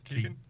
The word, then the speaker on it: Keoghan: Barry Keoghan, I think I've got his name right. – Keegan? – Yeah.